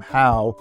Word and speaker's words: Hough: Why do you pronounce your name How when it's spelled H O U G H because that's how we spell our name and we pronounce it Huff? And the answer is I don't know.